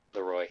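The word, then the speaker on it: Kid Laroi: So I think I'm saying that guy's name wrong. – Leroy. – Yes, I'm sorry. I know. I literally looked at his Twitter, and he says how to pronounce it on his Twitter. I just forgot, and I've just heard Lowry, so that's what I've been saying.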